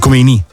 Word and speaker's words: Khomeini: Che all'epoca, io sono nato e cresciuto con l'Ayatollah Khomeini, nel senso che all'epoca si parlava solo di lui, l'Iran era Khomeini, anzi Khomeini, con l'accento sulla I.